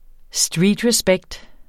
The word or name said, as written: streetrespect